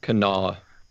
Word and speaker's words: Kanawha: Kanawha.